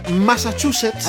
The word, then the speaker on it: Massachusetts: Pésimo, Pésimo. Massachusetts, ¿así? ¿ah, sí, sí, sí. A ver, dale, dale. Massachusetts. Ahí está, ahí está. Massachusetts. Así. Ah, Massachusetts. Massachusetts.